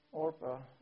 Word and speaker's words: Orpah: But the one, Orpah, I think is the way her name's pronounced, she decided, okay, she'd go back to her family.